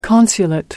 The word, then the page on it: consulate: American English